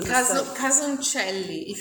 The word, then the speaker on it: Casoncelli: How do you pronounce, you know, the pasta dish with the crispy bacon? – Casoncelli. Casoncelli.